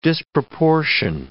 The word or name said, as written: disproportion